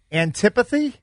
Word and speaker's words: antipathy: How do you say that word? – Antipathy? – Antipathy. So that means to racism. – It's antipathy. Yeah. Antipathy.